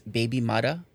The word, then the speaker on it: bebi mada: So we know they're saying that it originated in Jamaican Creole as baby mother, pronounced in Creole, I guess, baby mada, B-E-B-I-M-A-D-A, with the first printed usage appearing in the Kingston newspaper, the Daily Gleaner, in 1966.